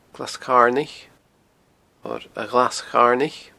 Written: Glas Carnaich

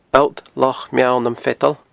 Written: alltlochmeallnamfeadan

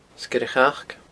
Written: Sgeir a' Chac

